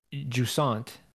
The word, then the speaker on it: Jusant: The Life is Strange devs are creating a new game called Jusant.